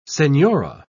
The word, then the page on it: senhora: British English